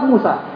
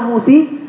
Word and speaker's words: Musa: mau kena angin, mau kena apa, tetap Musa. Tidak pernah musi, tidak pernah musuh. Tidak ada itu. Sebab dia ada bengkoknya di belakang Musa.